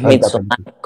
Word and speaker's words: Midsommar: Es Midsommar, con acento en la A.